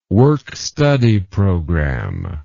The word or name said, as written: work study program